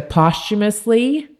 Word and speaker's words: posthumously: to posthumously, I said it right that time, dismiss the indictments for Ernest Thomas and Samuel Shepard, and to vacate the convictions for Charles Greenlee and Walter Urban.